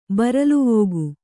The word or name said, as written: ಬರಲುವೋಗು